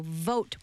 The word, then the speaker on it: vote: And I was like, no, vote with a V. Because he was like mispronouncing the V and the B. So remember, it's the same sound as boat, like bay, but with a V. So vote.